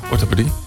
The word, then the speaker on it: orthopedie: En om te beginnen alleen bij de longafdeling en orthopedie. Orthopedie, zeg ik dat goed? Ja, orthopedie. Goed, in één keer goed.